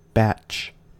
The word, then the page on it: batch: American English